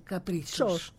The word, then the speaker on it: Caprichos: Καλά το είπα. Σου προφέρεται ή σου Καπρίτσος. – Τσος.